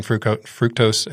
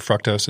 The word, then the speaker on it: fructose: fructose and or fructose, as it's often pronounced, and glucose.